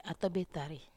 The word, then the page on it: atobetari: Wamesa